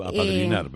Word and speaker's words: apadrinar: ¿Lo estoy diciendo bien? ¿O estoy a bloqueada? ¿Lo he dicho bien, apadronar? – Sí. Tú apadrinar, pero está bien dicho.